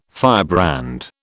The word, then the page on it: firebrand: British English